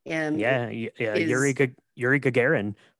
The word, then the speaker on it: Yuri Gagarin: Yuri Gagarin, first human in space.